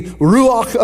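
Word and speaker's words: Ruach (רוּחַ): It is the Ruach of God. That's the word for spirit. It's R-U-A-H is how it's spelled in English, but it's pronounced kind of R-A-U-C-H. So there's this like sound at the end of it.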